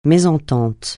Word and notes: mésentente: francais

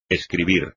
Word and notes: escribir: espagnol

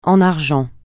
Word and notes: en argent: francais

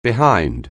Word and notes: behind: anglais

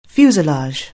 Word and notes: fuselage: anglais

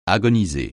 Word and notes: agoniser: French

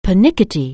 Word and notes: pernickety: anglais

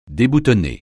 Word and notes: déboutonner: French

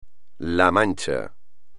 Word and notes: La Mancha: espagnol

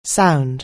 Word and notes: sound: anglais

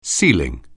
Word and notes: ceiling: anglais

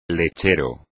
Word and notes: lechero: espagnol

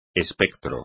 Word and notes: espectro: espagnol

